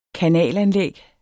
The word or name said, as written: kanalanlæg